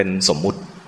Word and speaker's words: สมมติ: สมมุติสัจจะออกเสียงให้ถูกก็คือสมมติสัจจะคนไทยจะเรียกเป็นสมมุติมีใส่สระอุลงมาอีกคำถูกต้องคือสมมติสัจจะเป็นความจริงอีกระดับหนึ่งเป็นคนละระดับกันเป็นเรื่องของสมมุติถ้าบอกว่านี่คือพระกฤตคำว่าพระกฤตเนี่ยเป็นสมมุติ